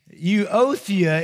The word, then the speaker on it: Euodia: Euodia is pretty much how you pronounce it.